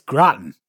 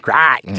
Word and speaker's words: Groton: Are you going to need to pronounce that, Carrie? It's Groton. – Groton. – Just imagine it being pronounced with an Australian accent. It's Groton.